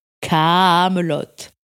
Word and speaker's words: Kaamelott: Alors très vite, ils se rendent compte que les gens appellent ça Kaamelott, et ça énerve Alexandre Astier, qui rajoute donc 2 A et 2 T afin que l'on comprenne bien que ça se prononce Kaamelott. Kaamelott.